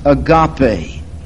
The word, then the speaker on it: agape: God's kind of love, which in English letters would be spelled A-G-A-P-E, pronounced agape. Agape.